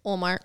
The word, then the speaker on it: Ullmark: Binner's going to get showed up in this game by Linus Ullmark.